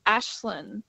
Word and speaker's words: Aislinn: So my name's pronounced Ashlyn, but it's spelled A-I-S-L-I-N-N. – Hey, let me write that down. A-I-S-L-I-N-N. A-I-S-L-I-N-N.